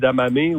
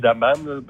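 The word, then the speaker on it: edamame: Les fèves de soya, les damamé ou les damames, peu importe la manière que vous le prononcez, est entré dans le dictionnaire.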